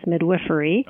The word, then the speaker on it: midwifery: I would start probably by saying that the correct pronunciation is midwifery. – Okay. – I don't know that it makes a big, big difference, but a little confusing. Midwife, midwifery.